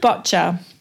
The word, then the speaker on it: boccia: So it's called boccia.